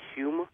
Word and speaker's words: Home: Hume, H-O-M-E, but spelled, pronounced Hume, like the stuff Hume.